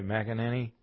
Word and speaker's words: McEnany: Kaylee McEnany, if you pronounce that right, McEnany.